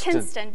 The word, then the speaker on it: Kinston: Well, the first game I was on, that last September, you said I was from Kingston. – And it's Kinston. – And it's Kinston